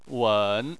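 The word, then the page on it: wen: wen3; tone 3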